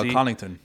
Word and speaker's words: Connaughton: No, how you pronounce it? – The uh, Connaughton. Connaughton, yeah.